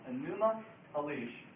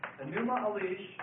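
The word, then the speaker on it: Enuma Elish: Excuse me, I spelled that wrong. Anuma Elish. Oh, no, I didn't. I did spell it right. Anuma Elish. And that's spelled E-N-U-M-A, and then a second word, Elish, E-L-I-S-H.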